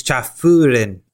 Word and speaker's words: Chafurin: It's Cha Furin. – Spell it. – C-H-A-F-U-R-I-N. Cha Furin.